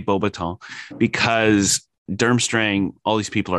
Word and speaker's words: Beauxbatons: Well, in French, just to let you know, we don't actually pronounce that S. It'll be Beauxbatons. – Okay. – Yeah. – Beauxbatons.